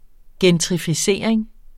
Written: gentrificering

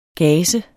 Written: gase

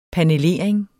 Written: panelering

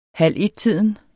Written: halvettiden

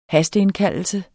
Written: hasteindkaldelse

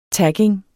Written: tagging

